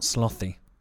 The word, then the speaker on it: sloth: I told you I cannot pronounce th. – Okay. Sloth. That's kind of sloth. Tricky. Iranians can't do the TH that well.